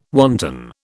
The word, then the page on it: wanton: British English